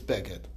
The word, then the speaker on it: beged: Okay, and Rabbi Kiva is over, Yeshem Lemakri, you go by how it's pronounced, which is, we have a tradition that it's with the chirik, so it's beged.